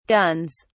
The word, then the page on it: guns: British English